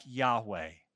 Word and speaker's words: Yahweh: And based on how Hebrew letters are pronounced, it was likely pronounced something like Yahweh.